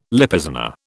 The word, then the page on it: Lippizaner: British English